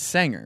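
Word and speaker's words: Senger: I would like to ask his ancestors because I'm pretty sure he pronounces it Singer, and it's Sanger, right? – I say Sanger.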